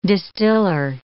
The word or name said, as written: distiller